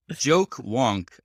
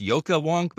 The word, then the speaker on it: Joke: Joke Wonk. I think it's pronounced Yoka Wonk, but it's spelled like J-O-K-E-W-O-N-K.